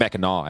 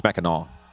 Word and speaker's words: Mackinac: It's Mackinac, actually. – What? – It's Mackinac. That's how they say it up there. – What? – Yeah, it's not like Mackinac.